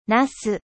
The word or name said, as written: aubergine